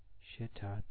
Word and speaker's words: shita: Tanda tsapo shita ju. Now hot very is. The second syllable should be stressed in the word shita. You will hear it this way on the tape.